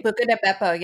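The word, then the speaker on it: Buca di Beppo: Buca de Beppo.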